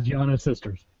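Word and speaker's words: Giana Sisters: Gianna Sisters.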